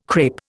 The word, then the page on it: crape: Indian